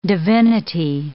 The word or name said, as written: divinity